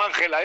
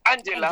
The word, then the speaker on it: Angela: Angela, que además se llama Ángela, no Ángela, es Angela.